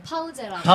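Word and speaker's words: Pause: 포즈를 어, 독일어 발음으로는 파우제라고.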